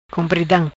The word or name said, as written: Compridam